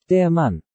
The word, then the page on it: Der Mann: German